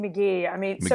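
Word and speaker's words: Magie: And part of the reason for the rest of the story is a woman by the name of Lizzie Maggie. Am I pronouncing that right? – Yes, Lizzie McGee.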